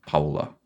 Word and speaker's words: Paola: Like Paolo, but Paola.